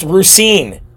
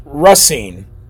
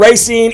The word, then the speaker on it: Racine: Uh, it's it's pronounced Rusine. I'm pretty sure it's pronounced Rusine. But either way, I don't live there, so I'm not gonna I'm not gonna argue with you. Rusine, Racine, either way, the the uh, sheriffs in this county actually uh, had some courage and they did what was right.